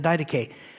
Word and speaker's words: Didache: One of the earliest writings about the Christian life and the attitude of Christians toward life is the Didache, or it's D-I-D-A-C-H-E. And Didache is how most people pronounce it.